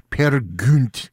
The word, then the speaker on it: Peer Gynt: Anitra's Dance from Peer Gynt by Edvard Grieg, or as Thomas Sundegaard says, Peer Gynt. That was his proper pronunciation on that one, and he would know, exactly.